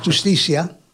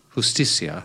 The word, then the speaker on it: justicia: Justicia. – Justicia.